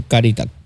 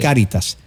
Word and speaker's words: Cáritas: Pero ha sido fácil, déjame explicarte, pero eso se hizo con Cáritas. Yo tengo problemas con pronunciar. – Es Cáritas. – Cáritas. – Lamentablemente, el otro día estaba escuchando. – No, todo el mundo lo dice igual que yo. Caritas.